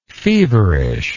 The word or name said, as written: feverishness